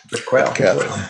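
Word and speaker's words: Prequelle: Pronúncia? Prequel. Aliás,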